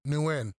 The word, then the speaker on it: Nguyen: Nguyen. The G. – Man, what is wrong with me? – Real G's moving silence like Nguyen.